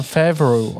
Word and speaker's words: Favreau: John Favreau. Favreau, zaraz zobaczymy. John Favreau. John Favreau, okej.